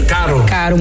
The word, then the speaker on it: caro: Falei caro, mas eu não pronunciei bem o R. Falei caro.